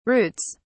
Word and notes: routes: British English

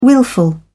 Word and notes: willful: British English

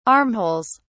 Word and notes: armholes: British English

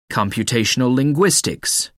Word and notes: computational linguistics: American English

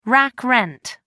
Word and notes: rack rent: American English

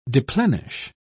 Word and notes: deplenish: British English

